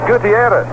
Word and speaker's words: Gutierrez: Actually, this Gutierrez, we're calling him, G-U-T-I-E-R-R-E-Z. He would pronounce it Gutierrez with the I. Here's a pitch. Oh, that one looked pretty good. Must have been high. It's 2. Curveball. The I in the name right after the T, G-U-D-I, would have to be pronounced. Gutierrez with a double R-E-Z.